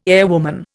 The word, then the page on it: airwoman: Indian